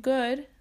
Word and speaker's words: good: La pronunciación de good es como G-A-D, good, en español. Y bye como ba-i, b-a-i, bye, con un i dicho muy rápido.